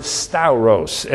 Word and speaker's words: stauros: The word in the Greek is the Greek word stauros, S-T-A-U-R-O-S, with an emphasis on the O-S, stauros, designated as a pointed, vertical, wooden stake firmly planted in the ground.